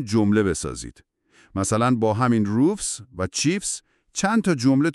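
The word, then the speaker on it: roofs: حالا به صدای آخر روفز گوش کنید. صدای FS میده. – روفز.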